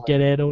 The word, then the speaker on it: Guerrero: You also have Chavo Guerrero Jr. I'm saying it like Guerrero now because that's how they say it.